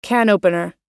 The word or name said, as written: can opener